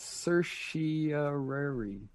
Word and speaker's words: certiorari: It's certiorari.